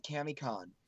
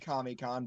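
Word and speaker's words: KamiCon: She's going to be at CamiCon down there. I know it's spelled CamiCon, but they say CamiCon. So how's that spelled? – K-A-M-I, like the Japanese god term, Kami.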